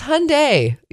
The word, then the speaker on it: Hyundai: Only 6% of people pronounce Hyundai correctly. It's Hyundai. Yep, I'm one of those. 94% who pronounce Hyundai wrong. Yeah, it's Hyundai. So if you want to say,